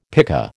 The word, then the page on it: pika: American English